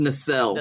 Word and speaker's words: nacelle: Tim went back to the nasal. Now what? Oh wait, no wait, that's a weird. – Hold on, it's it's pronounced nacelle.